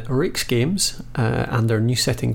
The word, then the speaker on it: RAEX: I kept saying R-A-E-X Games. – Oh, Rake's. People say Rake's. Rake's Games.